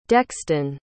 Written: dexton

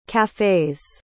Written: cafes

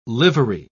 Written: livery

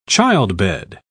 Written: childbed

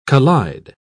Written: collide

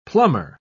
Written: plummer

